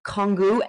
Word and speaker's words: Congou: And Princess Diana's favorite tea, and you're going to have to correct me, Gwendolyn, if I'm not saying this correctly, was the Rose Congou Emperor. Is that correct? Am I saying that right? – I think so, yeah.